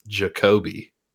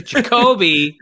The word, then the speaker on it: Jacoby: So, as someone who has lived in Missouri the full 32 years of my life, I know how to pronounce Jacoby. – Anyways, Jacoby Brissett was my dependable quarterback at that point in the season.